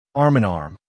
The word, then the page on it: arm in arm: American English